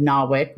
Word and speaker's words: NAWIC: And I saw Paula on a NAWIC panel and later found out that Paula actually founded NAWIC, which we pronounce NAWIC in the United States. I always have to explain, my listeners are all over the globe, that it's pronounced differently over here.